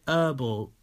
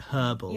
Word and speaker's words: herbal: So anyone in Canada, probably Laurie, can you let us know if you say herbal or if you pronounce the H like the proper way, like herbal? – Yeah, because it begins with an H.